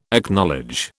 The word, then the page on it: Acknowledge: British English